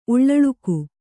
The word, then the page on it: ಉಳ್ಳಳುಕು: Kannada